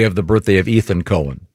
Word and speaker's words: Cohen: Leonard Cohen. – Cohen. Yeah, you. – This set's confusing because... – No, not when it's spelled C-O-H-E-N. – Yeah, it's definitely Cohen.